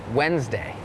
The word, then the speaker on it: Wednesday: Wednesday it has that D W E D It's not wed Wednesday Wednesday D is silent